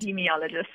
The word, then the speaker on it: epidemiologist: I'm an epidemiologist. – Epidemiologist. There we go. An epidemiologist and senior lecturer at UCT's Division of Epidemiology as well as Biostatistics.